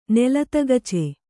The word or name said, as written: ನೆಲತಗಚೆ